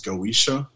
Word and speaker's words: Goetia: Like if you're dealing with um, magicians or sorcerers, they're dealing with a Goetia. You might also hear that pronounced as goisha.